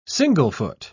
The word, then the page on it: single-foot: British English